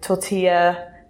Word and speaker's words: tortilla: And they do amazing tapas dishes, like prawn croquettes, chorizo, tortilla. Did I say that right? – Yeah, you did actually.